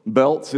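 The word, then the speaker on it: Bălți: Belts is spelled B-A-L-T-I, pronounced like plural, what I've got around my waist, belts. I know, but that's what it is, right?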